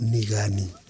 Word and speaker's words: Nigani: My name is Nigani. In my language, the G word in my name is sort of soft. So it's correctly pronounced Nigani.